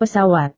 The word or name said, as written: pesawat